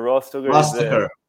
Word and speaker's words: Rostoker: Rostoker? Did I pronounce it correctly, Alex? – Yeah, Rostoker. – Rostoker.